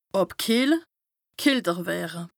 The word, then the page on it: àbkìehle / kälter wëre: Bas-Rhin; Strasbourg